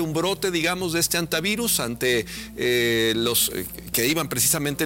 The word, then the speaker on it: hantavirus: ¿Cómo sería la pronunciación correcta de entrada? – En español es antivirus.